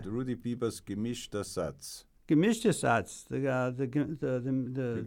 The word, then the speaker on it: Gemischter Satz: The Rudi Biber Gemischtesatz.